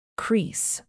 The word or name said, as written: crease